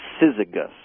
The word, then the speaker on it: syzygus: The Greek name or word is pronounced syzygus.